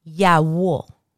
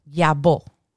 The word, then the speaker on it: Yawo: And in Spanish, I'm going to give you two pronunciations that you will hear. Yawo or yabo. The Cubans will a lot of the time say either yawo or yawo. I've also heard a yabo, like the Y, kind of like el yabo fue para la marqueta, like the yawo went to the market.